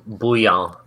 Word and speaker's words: Bouillon: Oh, that guy, Walter Winchell, called Joe Bion Joe Soup. Because it's like... – Nice. – Is that how you say it? The Bion? The Bouillon. – Bouillon. I'm sorry. I've been saying it wrong.